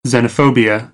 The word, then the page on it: xenophobia: American English